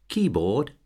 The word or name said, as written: keyboard